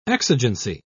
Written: exigency

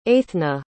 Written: Eithna

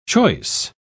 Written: choice

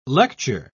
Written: lecture

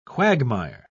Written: quagmire

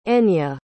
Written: enya

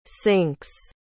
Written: sinks

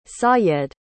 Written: sayed

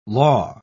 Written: law

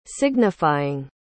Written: signifying

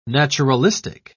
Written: naturalistic

